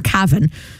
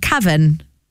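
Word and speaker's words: Kavan: It's K-A-V-A-N, which is either Kevin or Cavan. But if you say it in an English accent, Cavan.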